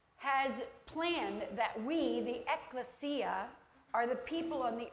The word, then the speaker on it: Ekklesia: Ekklesia. Ekklesia is the name of it.